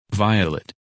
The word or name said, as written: Violet